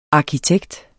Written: arkitekt

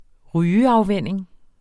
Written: rygeafvænning

